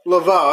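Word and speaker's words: LeVay: Lavoie, I don't know how to say it. – Gary Laveau? – Laveau, that's what it is.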